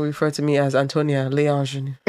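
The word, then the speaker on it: l'ingénue: Well, actually, in French, don't you say l'ingenue? Because l'ingenue. Once there's a A there, you don't pronounce the A. – The A, no. Yeah. If it's a l'ingenue. – L'ing. Because it's not. Le, and an L apostrophe.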